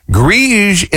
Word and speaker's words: greige: So if you're going to be painting inside, they say go with a, is it pronounced Grige? G-R-E-I-G-E? Grige is an updated and modern version of beige.